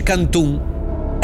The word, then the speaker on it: canton: Si dice canton, in tutti i canton. – Ragazzi, ma mi viene da piangere. – Qualcuno però dice cantun. Canton. Canton c'è scritto, la pronuncia canton.